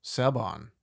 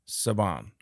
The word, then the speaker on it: Siobhan: So they spell it S-O-S-I-O-B-H-A-N. – Saban. – Saban.